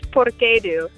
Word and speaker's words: Porquedu: It's Porquedu. It's actually Italian.